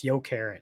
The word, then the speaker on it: Jokerit: How do you pronounce it again? – Jokerit, but it's Jokerit.